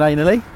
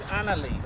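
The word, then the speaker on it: Anerley: Sort of Do you live around Ainley? – No, definitely not. – Oh, not good down there. – Huh? – Not good down there then. – Well, it's called Annerley. That's how you say it.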